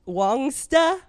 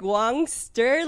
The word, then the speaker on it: Wongsta: And my coach being Australian, it's pronounced Wongsta. – Wongsta. Oh, kind yeah. of like. It sounds better with an Australian accent. – I always thought he was just saying like Wongster, like Wongster.